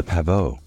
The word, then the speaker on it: pavot: Poppy flowers in French are pronounced fleur de coquelicot and spelled fleur, F-L-E-U-R-S, de, coquelicot, C-O-Q-U-E-L-I-C-O-T-S. Poppy seeds are pronounced graine de pavot and spelled graine, G-R-A-I-N-E-S, de, pavot, P-A-V-O-T.